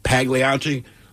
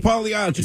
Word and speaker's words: Pagliacci: But when I call there to get a delivery, and 100% of the time they answer the phone and say, Pagliacci, like anything, if that's how they say it, that's how I'm going to say it. – Exactly. – That's all, man. You're right. It is Pagliacci.